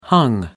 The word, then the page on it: hung: American English